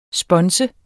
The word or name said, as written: sponse